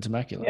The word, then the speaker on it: Temecula: So you live in Temecula.